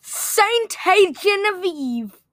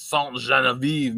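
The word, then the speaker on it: Sainte-Geneviève: Saint-Genevieve. I think I said that right. – Well, if you're French, it's Saint-Genevieve, Missouri. But anyway, number 7, go ahead. – Saint-Genevieve, Missouri. Saint-Genevieve is a city, state in S-T-E dot.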